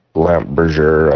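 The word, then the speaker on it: Lampe Berger: Yeah, that is pronounced, or sorry, spelt L-A-M-P-E and then B-E-R-G-E-R. And I, looking at it, I would say something different, but it's pronounced lamp Bergère, I guess. – I'm French, and lamp is lamp, like a light lamp.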